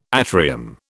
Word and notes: atrium: British English

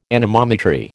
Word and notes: anemometry: American English

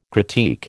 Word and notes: critique: American English

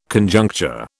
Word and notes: Conjuncture: British English

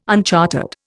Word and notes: unchartered: Indian

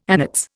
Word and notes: annates: Indian

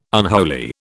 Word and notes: unholy: British English

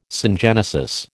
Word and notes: syngenesis: American English

